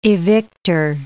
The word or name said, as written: evictor